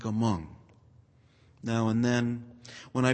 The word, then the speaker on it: Hmong: By the way, some of you may have seen this word Hmong written out before. It's H-M-O-N-G, but the H is silent.